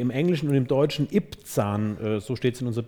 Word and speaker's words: Ibzan: What, how would you pronounce that? – Ibzan. – Ibzan.